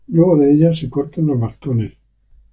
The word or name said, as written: cortan